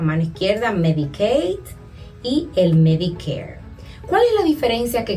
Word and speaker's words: Medicare: Y está el Medicare, terminado en air, siempre, me encanta pronunciarlo, y este es un beneficio federal.